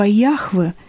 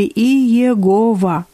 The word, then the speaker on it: Яхве: Причем по правилам еврейского языка первое «а» стало произноситься как «э». Так вместо слова «Яхве» возникло прочтение и написание «Еховах» или «Иегова».